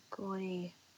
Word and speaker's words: Gordi: And somebody that I really latched on to that I like a lot is Gordy, I think is how you pronounce it. – Gordy. – I've heard that name. G-O-R-D-I.